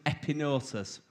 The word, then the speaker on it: epignosis: It's epignosis, but the G is silent, so it's epinosis.